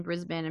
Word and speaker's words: Brisbane: Lisa, that's excellent pronunciation of Brisbane. – Of what? What did I say? – You said Brisbane. – I did? – That's how you say it. You didn't say Brisbane. – Oh, okay. – Most Americans would say Brisbane or Brisbane or something. Yay. But Brisbane, yay.